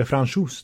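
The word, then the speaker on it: Francouz: Men Frankos, han, eller Franchos tror jag man ska. Man ska uttala det som en fransos helt enkelt.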